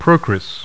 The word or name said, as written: PROCRIS